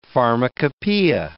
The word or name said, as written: pharmacopoeia